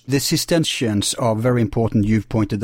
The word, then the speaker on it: Cistercians: No, I'm pronouncing it badly, but Cistercians.